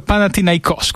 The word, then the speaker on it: Panathinaikos: E ho chiesto, e l'accento è proprio Panathinaikos, quindi sono Olympiakos e Panathinaikos.